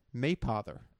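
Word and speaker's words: Mapother: Is this like We learned recently that it's actually pronounced Maypother. – Maypother?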